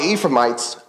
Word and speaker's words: Ephraimites: It says, now the Ephraimites, I think that's the correct pronunciation, we're going to call them that.